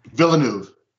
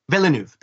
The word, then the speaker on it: Villeneuve: The new director is Villeneuve. – Villeneuve, thank you.